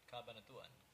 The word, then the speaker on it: Cabanatuan: Cabanatuan.